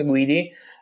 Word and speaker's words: Guidi: Uh, my name is uh, Luca Guidi.